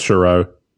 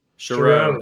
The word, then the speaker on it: Chiarot: Florida Panthers today signed or traded for Ben Chiarot. I can't say his name. – Sure. I think it's Chiarot. – Chiarot. That sounds right.